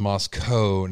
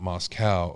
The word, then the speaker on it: Moscow: Moscow is pronounced Moscow, not Moscow.